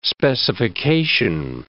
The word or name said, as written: specification